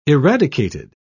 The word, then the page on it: eradicated: British English